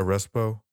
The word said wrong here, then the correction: reposado: Yeah, reposado. – Fuck! I've been saying it wrong the whole fucking time.